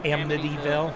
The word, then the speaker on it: Amityville: Amityville.